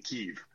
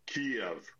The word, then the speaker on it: Kyiv: it is not as easy as simply saying Kyiv. It's not a single-syllable city. It is Kiev.